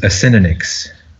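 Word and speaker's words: Acinonyx: The official pronunciation, according to Google, is Asininix.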